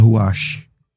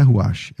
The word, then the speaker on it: ruach: Tem o pessoal que fala ruach, só que não é ruach, é ruach. Tá errado. Então a pronúncia errou. Você lembra do ruach? Não é? O pessoal fala ruach. tá.